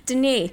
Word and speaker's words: Denis: Denis. – Denis Villeneuve. Not Dennis. It's Denis. – No, it's Denis.